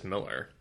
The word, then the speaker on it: Millar: And actually, I guess it's just Miller. It's just Mark Miller. It's just that it's spelled with an A, but it's still pronounced Miller.